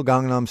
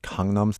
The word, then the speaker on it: Gangnam: Not Gangnam Style, but Gangnam Style? – The word in Korean, the letter is kind of halfway between a G and a K.